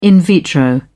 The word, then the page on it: in vitro: British English